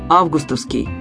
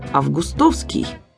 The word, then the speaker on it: августовский: Там нам советуют говорить «августовский» с ударением на первом слоге. Собственно, этот же вариант стоит на первом месте и в орфоэпическом словаре под редакцией Аванесова «августовский». Но здесь нам предлагают еще один допустимый вариант – «августовский».